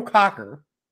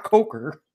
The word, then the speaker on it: Cocker: His name is universally pronounced in all languages, Joe Cocker, not Coker.